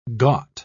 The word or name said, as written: ghat